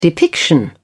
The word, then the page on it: depiction: British English